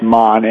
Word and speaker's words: Maan: The other one I was looking at was, I think it's pronounced Maan, M-A-A-N, with Rajiv Murat.